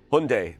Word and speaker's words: Hyundai: Hyundai. Hyundai. Say it. Hyundai. That's how you say it.